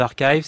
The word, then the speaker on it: arXiv: En fait, c'est vieux, c'est 1991, c'est la naissance d'Archive qu'on écrit Arxiv. Et qu'on dit Archive parce que c'est les physiciens qui le prononcent comme ça.